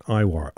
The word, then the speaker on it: Eihwar: And we could use Ono's voice now, and we start out with Iwar. Right, okay, a few words about this band. Iwar, spell it again, E-I-H-W-A-R.